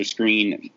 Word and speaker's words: Scrine: And then you'll have Buster Screen or Scrine matched up with – it's Buster Screen. I don't know why I always say Scrine. It's spelled Scrine, but it's pronounced Screen.